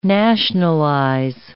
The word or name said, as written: nationalize